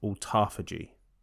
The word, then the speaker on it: autophagy: And I'm looking at a thing now that says it's supposed to be pronounced autophagy.